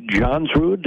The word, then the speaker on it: Johnsrud: Well, we've got with us from the North Star Youth Exchange with the Rotary, right here in Northfield, Eric Johnsrud.